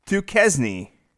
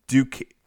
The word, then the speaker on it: Duquesne: His name's Fritz Duquesne.